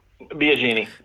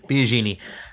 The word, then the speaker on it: Biagini: Is that how you say it, first of all? Is it Biagini? – Yeah, Biagini. – Biagini.